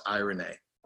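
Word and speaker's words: eirene (εἰρήνη): It's spelled with our the alphabet characters, the ones that we're familiar with, E followed by the actual name Irene, but pronounced eirene. The root of that word comes from Iro, E-I-R-O, which means to join together and make whole.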